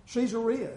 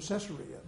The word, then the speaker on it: Caesarea: Caesarea is the way he pronounced it. I remember that. I've always heard it Caesarea, but it's named after Caesar.